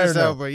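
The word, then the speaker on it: Idris Elba: Idris Alba, yeah.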